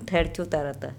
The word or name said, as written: ThErcotarata